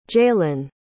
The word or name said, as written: jaelyn